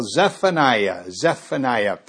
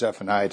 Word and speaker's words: Zephaniah: You know, the I-A-H, like Nehemiah or Jeremiah or Zephaniah. In the Hebrew, I believe it's Y-A-H on the end, Yah, standing for God, Yahweh. And so probably a better Hebrew pronunciation of this word would be Zephaniah.